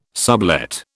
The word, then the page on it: sublet: British English